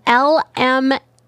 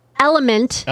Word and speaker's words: LMNT: Element. – Element. – It's spelled LMNT.